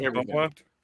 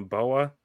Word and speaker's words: BOA: Is it all pronounced Boa? Like, I'm going to go watch some Boa. – Boa. This is BOA. It's what we call it. It's BOA.